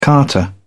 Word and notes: carter: British English

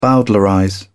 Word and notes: bowdlerize: British English

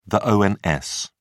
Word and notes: ons: British English

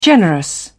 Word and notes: generous: British English

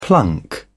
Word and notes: plunk: British English

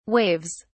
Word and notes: waves: British English

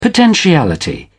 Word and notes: potentiality: British English

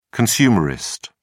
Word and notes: consumerist: British English

